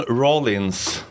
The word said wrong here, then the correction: Rawlinson: Är det korrekt uttalat? – Rawlinson.